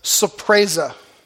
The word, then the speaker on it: Surprise: And the evangelist's name was Sapreza. Sapreza is spelled surprise.